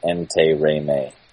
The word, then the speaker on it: Interami: So it looks really difficult, but it's just same vowel sound, Interami.